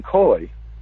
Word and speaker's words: Kui: We're talking about Lau Wing Khoi, which is pronounced differently than it's spelled, K-U-I.